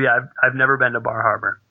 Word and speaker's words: Bar Harbor: Here I am at Bar Harbor. – Let me say that for you, Todd. Bar Harbor. – I pronounce the R's. There's distinct R's in there.